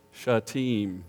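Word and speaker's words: Shittim: How do we say that word? Shatim, yes.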